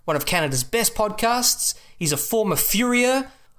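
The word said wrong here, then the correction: farrier: And it's pronounced Ferrier, just so you know. – Ferrier.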